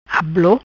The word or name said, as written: Ablo